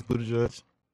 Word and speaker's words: Buttigieg: I was just like, oh yeah, that makes sense, Buttigieg.